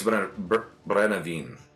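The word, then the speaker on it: Brennivín: I think it's Brennevin. – Brennevin? – Yeah. – That sounds right.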